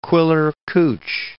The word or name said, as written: Quiller-Couch